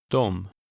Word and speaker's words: De: This word is pronounced very differently from the way it's spelled. – Dom.